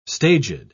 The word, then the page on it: staged: British English